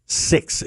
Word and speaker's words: Syx: Six, it's spelled S-Y-X.